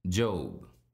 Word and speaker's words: Job: It's just three letters, J-O-B. For those familiar, or rather less familiar with the Bible, it's common to think that his name is Job. Be advised, however, aided by an uppercase J, that actually the man's name is pronounced Job.